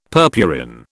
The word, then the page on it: purpurin: British English